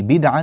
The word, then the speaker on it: بدعا: أيضا في الآية التاسعة قوله تعالى قل ما كنت بدعا، بدعا، هذه بدعا بدال ساكنة، لا نقول بدعا، لا نفتح الدال.